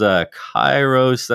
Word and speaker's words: Kayrros: From a company called Kairos, that's spelled KAYYROS.